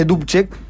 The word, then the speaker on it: Dubček: Pero finalmente el régimen militar, que también todavía sobrevive en Checoslovaquia, captura a Dubček, en realidad se escribe Dubček, tiene como una, sí, como un tildecito, creo que se dice Dubček.